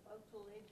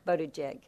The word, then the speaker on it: Buttigieg: They were interviewing Mr. Botulidge, I can't pronounce his name, running for president. – Mayor Pete. – What? – Mayor Pete. – Whatever. – Buttigieg.